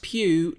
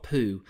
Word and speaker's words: Pugh: She actually went on Instagram this week and said, it's pronounced pew like Hugh, but with a P, and no, it doesn't, it's not poo.